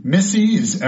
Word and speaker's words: misease: Misease, M-I-S-E-A-S-E, rhymes with disease, and it's discomfort. Miseats, M-I-S-E-A-T-S, to eat improperly. Misedit, to edit badly, M-I-S-E-D-I-T. Misform, M-I-S-F-O-R-M, to form badly.